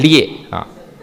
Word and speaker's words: 劣: 最后这个甚如、啊、应该是甚略的意思，甚略略啊，就是殊胜跟很差，略啊，劣啊，哦，劣啊，发音不标准，甚劣啊，